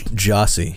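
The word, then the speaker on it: Gyasi: I might mispronounce it. Gaiacy? – Jossie. – Jossie.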